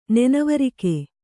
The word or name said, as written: ನೆನವರಿಕೆ